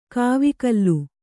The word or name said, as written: ಕಾವಕೂಲ